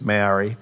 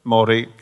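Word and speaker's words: Māori: And uh, then on Thursday afternoon, we had a Maori, um, that's for you who don't understand, it's Maori. Uh, to say it properly, it's Maori. Uh, isn't that correct? Maori, yep.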